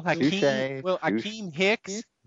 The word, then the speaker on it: Akiem Hicks: Will Akeem Hicks be the Mountain for the Saints this year? – I did.